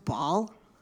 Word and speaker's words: Basel: Or I guess that's pronounced Ball. They were talking about that on the Total Soccer Show like last year. So I don't know. But I'm going to call it Basel for everyone's sake.